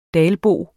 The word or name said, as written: dalbo